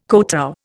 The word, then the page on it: kotow: Indian